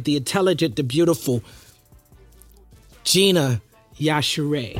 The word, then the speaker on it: Yashere: But Yashere. – You did well. Just think Yashere. – Yashere. – Yeah. – But Nigerians pronounce it much more aggressively. Okay. Yashere. That's how it's supposed to be pronounced.